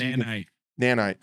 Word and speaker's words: nanite: So this new operator named Fenrir from Sweden has a nanite gadget that he puts out, and essentially what it does, Nanite. Come on, man. – Get it together. So, sorry, that was the southern pronunciation. It is a nanite.